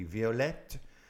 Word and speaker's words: violette: And that is violet, and of course the feminine form, we would pronounce that T at the end, those two T's would be violette and dark.